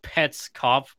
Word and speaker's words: Petscop: Or Petscop, like scope without the E. – The pun is Petscop, but Petscop is how you say it, basically.